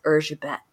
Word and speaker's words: Erzsébet: So little known maybe fact there, but if you want to say her name as it's really pronounced, like, it's not Elizabeth Bathory, it's Erzsébet, which I think is very fun to say.